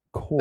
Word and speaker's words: Corps: Core. – That's a weird word. The P and the S are silent. Core.